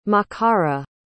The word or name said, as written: makara